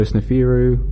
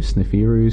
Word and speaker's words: Sneferu: And so Sneferu, that's what I'm calling it, but it's spelled S-N-E-F-E-R-U, Sneferu, Sneferu.